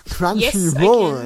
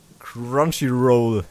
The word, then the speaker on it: Crunchyroll: zunächst einmal, dass Detektiv Conan jetzt als Simulcast bei Crunchyroll, habe ich es richtig ausgesprochen, in den USA und Kanada verfügbar ist. Da weißt du Näheres zu, nicht wahr? Darfst du auch gerne mal richtig aussprechen? – Ja, also ich habe auch lange kein Englisch mehr gehabt. Crunchyroll, ja.